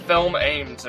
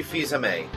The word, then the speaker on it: Fils-Aimé: It looks like Film Ames or something like that. Yeah. – No, it's Reggie Fils-Aimé. That's how media outlets have pronounced it.